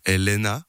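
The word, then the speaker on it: Elæna: Alors en fait, ça se prononce Elaena. – Elaena, comme okay. Le prénom.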